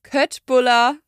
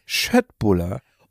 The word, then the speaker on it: Köttbullar: Es gab noch Ikea, die haben mit Chupa Chups zusammen ein, ich sage übrigens, Köttbullar Lolli rausgebracht. – Das ist aber falsch. Jetzt darf ich einmal die Aussprache korrigieren. – Ja, was sagt man eigentlich? – Köttbullar.